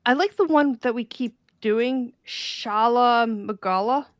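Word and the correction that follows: Shagaru Magala: Did I say that right? – Shigaru Magala.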